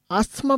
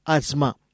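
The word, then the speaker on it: asthma: এহাকু ভুলরে অনেক আস্থমা বলি কহন্তি, কিন্তু এর প্রকৃত উচ্চারণ হল আজমা।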